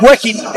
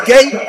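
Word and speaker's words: Guéhi: Con su apellido y la pronunciación, no es Wehin, es Gay.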